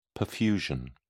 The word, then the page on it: perfusion: British English